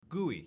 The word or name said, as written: GUI